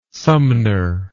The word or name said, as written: Sumner